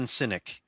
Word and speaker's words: Sinek: Like, there's some guys that I follow, like Simon Sinek.